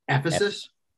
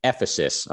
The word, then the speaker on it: Ephesus: Ephesus. – Ephesus.